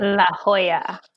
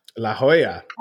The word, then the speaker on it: La Jolla: I think is how you say it. – La Jolla. – La Jolla. – La Jolla. I had to be corrected on that too when I booked my trip.